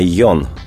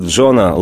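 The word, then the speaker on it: Jon: Родился Джон Дуглас Лорд, вообще-то пишется не Джон, а Йон, но мы говорим Лорд, подразумеваем Джон.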